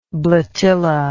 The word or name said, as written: bletilla